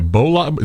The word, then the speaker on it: bolide: A bolide is an exceptionally bright... I don't even know if we're saying it right. Bolide? Is it... – Bolide. – A bolide. Okay.